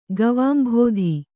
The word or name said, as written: ಗವಾಂಭೋಧಿ